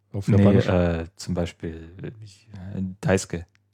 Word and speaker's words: Daisuke: Daisuke. Ja. Das wird immer sehr kurz ausgesprochen.